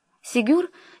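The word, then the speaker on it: Ségur: Сигюр — типичная французская фамилия с ударением на последнем слоге и частичкой «д», свидетельствующей об аристократическом происхождении.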